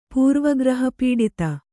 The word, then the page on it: ಪೂರ್ವಗ್ರಹಪೀಡಿತ: pūrva graha pīḍita